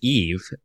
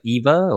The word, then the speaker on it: Eve: Technically her name is Eve, but the way he pronounces it sounds like Eva or... – Eva. It sounds like Eva, but yeah, it is Eve.